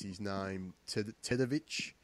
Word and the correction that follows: Teodosic: from... Teodosic, from Serbia.